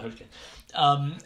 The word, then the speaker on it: Hoechlin: I don't know how to pronounce his surname. – Hoechlin? – Yeah, I'm going to say Tyler Hoechlin.